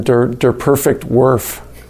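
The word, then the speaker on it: Der perfekte Wurf: The movie's called Nowitzki, The Perfect Shot, or in German, Der Perfekt Wurf. That's how you say it in German.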